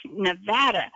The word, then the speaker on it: Nevada: I have said for an entire lifetime Nevada, and Nolan Kenner called in one night and said, nope, it's Nevada. And that was the first time I ever knew that. I never paid attention to how people were pronouncing it. – Actually that's a Spanish word that means snow. And it would be pronounced, I would pronounce it Nevada, but... – And Nevada is exactly what Nolan said.